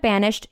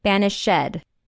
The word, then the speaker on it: banished: Instead of banished, she repeats the pronunciation banished.